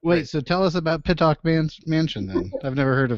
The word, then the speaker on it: Pittock: I don't even know if I pronounced it right. Is it Piddick or is it Pittock? – Pittock. – Oh, Pittock.